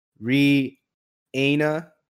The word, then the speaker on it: Rihanna: I mean, I think it's a dope thing to kind of celebrate is Re-Ana is the correct way to say it.